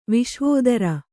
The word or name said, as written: ವಿಶ್ವಾಧಾರ